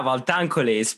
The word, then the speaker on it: Valtancoli: Yeah, Valtancoli.